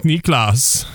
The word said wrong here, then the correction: Nicklaus: That's your German 101 coming back in from KU. – Let's be real. There's a whole lot of nothing in the golf world between Jack Niklas and... Oh, geez, I can't even say it right now. Between Jack Nicklaus and Tiger Woods.